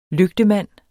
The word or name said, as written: lygtemand